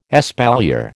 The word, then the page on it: espalier: American English